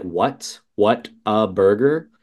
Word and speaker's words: Whataburger: They pronounce it weird. So it's spelled like what a burger, but everybody calls it Whataburger.